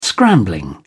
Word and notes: scrambling: British English

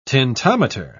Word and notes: tintometer: British English

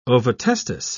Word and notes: ovotestis: British English

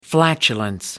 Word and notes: flatulence: American English

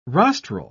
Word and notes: rostral: British English

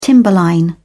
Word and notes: timberline: British English

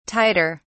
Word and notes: tighter: British English

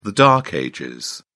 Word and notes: dark ages: British English

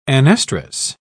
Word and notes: anestrus: British English

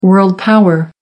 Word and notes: world power: American English

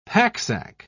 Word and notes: packsack: British English

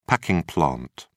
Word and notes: packing plant: British English

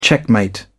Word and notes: checkmate: British English

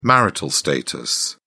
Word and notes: marital status: British English

